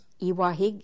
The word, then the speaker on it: Iwahig: Iwahig is spelled I-W-A-H-I-G.